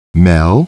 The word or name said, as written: Mel